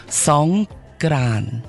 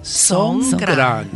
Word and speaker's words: Songkran: Right, it's actually called Songkran. – Songkran. – Yeah, it's a G, not a K. Yeah. Songkran. – Oh. – Interesting. – Right, so Songkran actually is a Thai New Year's,